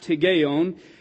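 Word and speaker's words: Higgaion: This first word, hygeion, is the way I see it in here, and that's pronounced hygeion.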